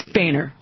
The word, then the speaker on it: Boehner: Well, wait a minute. It's Boehner. – Yeah, it's Boehner. – How do you pronounce H-O-E-H-N-E-R? – Boehner.